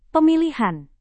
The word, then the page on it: pemilihan: Indonesian